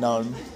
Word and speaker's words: GNOME: I haven't configured i3 yet, but I've got GNOME up and running. – I mean GNOME. – GNOME. – Why is it GNOME? – Because that's how the smart people pronounce it.